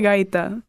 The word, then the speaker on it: gaita: Probably a gaita, which isn't it's like a genre of music.